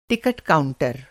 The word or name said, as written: टिकट काउंटर